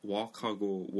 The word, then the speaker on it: walk: walk이라고 하면은 솔직히 그 l 발음 안 살려요. 말할 때 그냥 walk. 그 l 없는 것처럼. I went for a walk today. 그러니까 l 안 들리잖아요.